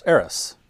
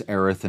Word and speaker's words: Aerith: And her name is, of course, Eris, right? – Aerith. So yeah, in the original game, there's an S in it. – Right. – And so for years we pronounced it that way.